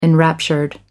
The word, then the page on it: enraptured: American English